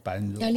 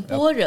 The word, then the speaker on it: 般若: 啊，这个般若呢就不能念成般若，要念般若的。 – 对，般若哈，这很麻烦。为什么？那可是哈，大家大家会觉得麻烦，就是说一出来。这只认识两个字“ 心经”，对不对？ – 对。 – 然后般若波罗蜜，那我们在啥？